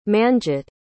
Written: manjit

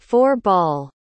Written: four-ball

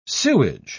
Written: sewage

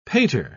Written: pater

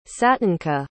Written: satinka

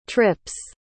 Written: trip's

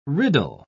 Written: riddle

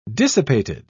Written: dissipated

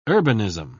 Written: urbanism

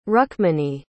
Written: rukmini

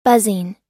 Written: buzzing

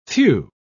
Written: few

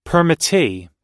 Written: permittee

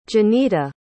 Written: jonita

